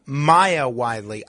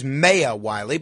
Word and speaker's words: Maya Wiley: I always thought it was Maya Wiley, but I'll go along with the AOC pronunciation and assume it's Maya Wiley.